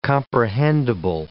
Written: comprehendible

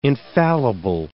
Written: infallible

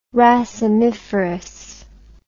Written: racemiferous

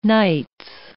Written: nights

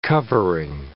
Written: covering

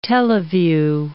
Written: teleview

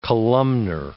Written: columnar

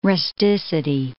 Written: rusticity

